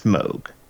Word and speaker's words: Moog: It's spelled Moog. It's pronounced Moog.